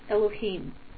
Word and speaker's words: Elohim: It's spelled Yud-Heh-Vav-Heh, but it's pronounced Elohim.